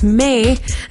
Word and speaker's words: Maed: My name is May, and it's spelled M A E D.